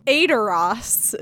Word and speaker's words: Edoras: And so now the next part of doing that task is going to Edoras, is basically how they say it.